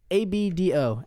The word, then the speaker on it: Abdo: Abdo. A B A B D O.